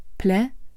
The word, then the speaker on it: Plädoyer: Die Schlussreden heißen in schwerer Sprache Plädoyer. Das wird so ausgesprochen. Plädoyer. Die Generalstaatsanwaltschaft hat in ihrer Schlussrede gefordert, der Angeklagte Taleb A.